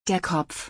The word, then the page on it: der Kopf: German